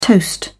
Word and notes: toast: British English

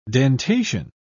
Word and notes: dentation: British English

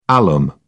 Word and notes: alum: British English

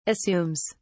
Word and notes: assumes: British English

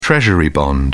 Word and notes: treasury bond: British English

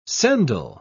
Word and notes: sendal: British English

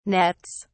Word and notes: nets: British English

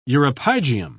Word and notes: uropygium: British English

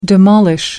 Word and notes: demolish: American English

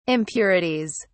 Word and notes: impurities: British English

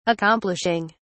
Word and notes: accomplishing: British English

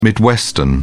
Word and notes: midwestern: British English

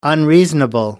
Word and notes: unreasonable: American English

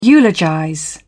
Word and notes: eulogize: British English

